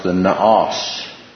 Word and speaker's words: naos: One is what's called the inner temple, and it's pronounced the naos.